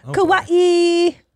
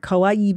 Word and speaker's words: kawaii: It's not kawaii. It's kawaii. Say it right.